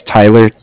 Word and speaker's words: Tyler: My name is Tyler, T-Y-L-E-R, and I'm calling with Cardiff. It's spelled C-A-R-D-I-F-F, as in Frank Frank.